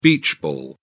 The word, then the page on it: beach ball: British English